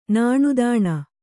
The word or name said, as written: ನಾಣ್ದಾಣ